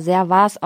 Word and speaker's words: Servaes: Das wird auch, also mein Kompagnon heißt ja Servas mit Nachnamen und das wird also S-E-R-V-A-E-S geschrieben und wird aber Servas ausgesprochen, nicht Servas.